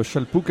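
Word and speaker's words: Szalpuk: Donc, Johanna Chalpouk,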